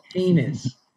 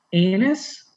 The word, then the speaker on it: heinous: I don't, is heinous, do I have to say H on heinous? Is there an H? – There is an H on that. – Heinous. I said it right? Heinous? – Yeah, you don't want to say it without the H, and it sounds something like something else.